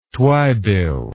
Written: twibill

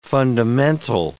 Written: fundamental

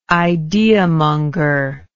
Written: ideamonger

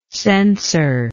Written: censerless

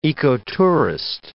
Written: ecotourist

